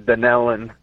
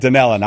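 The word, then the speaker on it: Dunnellon: I'm going to go with Dunalon, Florida, watching on YouTube. I hope I said the town right. I have not heard of that town before. – No, actually, it's pronounced Dunellen.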